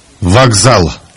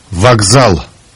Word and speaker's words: Вокзал: Вокзал. Вокзал.